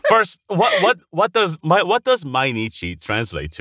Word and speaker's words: Mainichi: Am I saying that correctly? Mainichi? – Mm-hmm. Yeah, Mainichi, yeah.